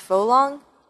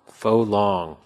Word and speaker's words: Phuong Long: I've been told that it's pronounced Folong. – Folong. – But it's spelled like Phuong Long.